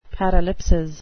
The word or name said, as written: paralipsis